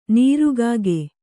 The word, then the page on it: ನೀರ್ಕಾಗೆ: nīrkāge